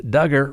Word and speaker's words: Duggar: And for those who aren't familiar with it, a federal jury in Arkansas found Duggar, rhymes with hugger, guilty in December of 2021 related to the receipt of those inappropriate images involving small children.